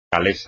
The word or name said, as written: calesa